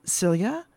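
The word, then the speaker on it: Silje: And I hope I'm going to pronounce her name right. Cilia? – Yeah, that's exactly right. – Oh, excellent. Bravo. It's S-I-L-J-E for anyone out there listening, a good Scandinavian name.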